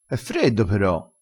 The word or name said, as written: pero